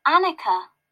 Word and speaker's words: Anika: Annika. – Can you spell that? – A-N-I-K-A.